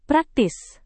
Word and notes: praktis: Indonesian

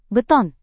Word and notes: beton: Indonesian

